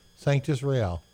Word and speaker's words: Sanctus Real: Sanctus Real.